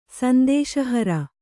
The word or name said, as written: ಸಂದೇಶಹರ